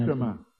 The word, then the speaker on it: parikrama: I just wanted to thank you for taking us on a parikram. That was so sweet. – And we didn't even parikrama. – Parikrama, whatever. – It's a long A in the end, so you can't drop it.